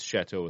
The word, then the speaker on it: Chateau: Bayou Oaks at City Park, and I'm going to mispronounce this, Chateau Golf and Country Club. – Oh, it's Chateau without the extra letters. Okay. So very French. I should have picked up on that.